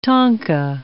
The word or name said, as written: tanka